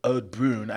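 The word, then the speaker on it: Oud Bruin: reminiscent of an Ode Brune. I never know if I'm saying that right. I wish somebody would correct me. – Sounds right. Ode Brune.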